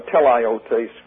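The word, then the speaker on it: teleotes (τελειωτής): Perfector is the way we're going to translate teleotes, a teleotes correctly pronounced. Teleotes.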